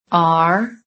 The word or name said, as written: ARE